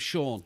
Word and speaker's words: Sean: But all of it, you can spell it however you want, but S-E-A-N is seen.